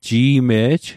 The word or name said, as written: Ji'me'j